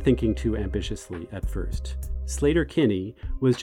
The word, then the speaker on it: Sleater-Kinney: By the way, locals pronounce the name of the road Sleater-Kinney, not Sleater-Kinney, and that's how the band has continued to say the name.